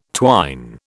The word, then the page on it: twine: British English